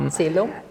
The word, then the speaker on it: Celum: The name is derived from a Latin word. – Mm-hmm. Celum. – Exactly. Uh-huh. So actually, if we would be in old Rome, we would probably call it Celum because the C is a K.